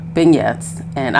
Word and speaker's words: beignets: Beignets? I always say it wrong. Beignets. And I had never had one in my life.